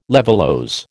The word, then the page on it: levulose: American English